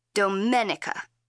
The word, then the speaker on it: Domenica: My name is pronounced in the Italian way, Domenica.